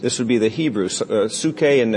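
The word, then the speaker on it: psuche (ψυχή): And the word for soul is the word psuche. And it's pronounced psuche, but if you transliterate it, it would look like this. And you've seen that word. It says, we would pronounce it psyche. But it comes from the Greek word psuche.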